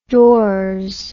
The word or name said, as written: dors